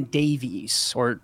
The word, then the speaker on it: Davies: And then there's a great book by philosopher Brian Davis. It's spelled D-A-V-I-E-S.